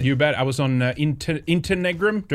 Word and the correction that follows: Interregnum: Do I pronounce that correct? – Yes, Interregnum.